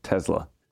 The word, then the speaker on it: Tesla: She always says it Tesla with a Z.